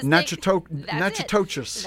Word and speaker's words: Natchitoches: And Jeremy from Louisiana, I can't pronounce the city name, he says, Natchitoches.